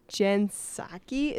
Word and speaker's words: Psaki: His press girl, Jen Psaki, is that how it's pronounced? – Yes.